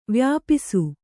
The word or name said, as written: ವ್ಯಾಪಿಸು